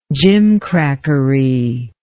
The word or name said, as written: gimcracky